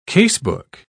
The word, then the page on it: casebook: British English